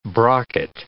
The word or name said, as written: brocket